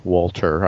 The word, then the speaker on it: Walther: Walter, I know you don't pronounce the H, but uh, it's a Walther PPK.